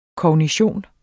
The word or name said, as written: kognition